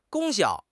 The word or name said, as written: 功效